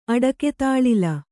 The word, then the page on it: ಅಡಕೆತಾಳಿಲ: kannada